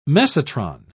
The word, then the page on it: mesotron: British English